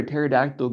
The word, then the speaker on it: pterodactyl: While we're here, why can't you hear a pterodactyl go to the bathroom? Because the P is silent.